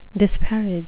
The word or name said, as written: disparage